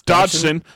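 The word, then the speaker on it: Dodgson: Wong comes back as Dr. Henry Wu, Campbell Scott as Lewis Dogson. Does that last name sound familiar to anybody? – I mean, you said it wrong. Because it's Dodson. Dodson. We've got Dodson here.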